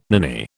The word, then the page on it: ninny: British English